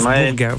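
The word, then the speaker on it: Burgas: Alors Burga, on aurait pu l'accepter parce que tout simplement ça s'écrit BU, hein, mais ça se prononce Burga, voilà, tout simplement.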